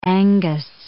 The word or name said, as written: Angus